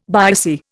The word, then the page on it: biopsy: Indian